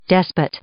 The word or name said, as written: despot